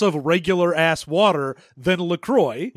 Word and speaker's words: LaCroix: You'd think, I bought like a fucking pallet of LaCroix or La Croix or whatever. I know you're not supposed to pronounce it like it's a French word because it's not.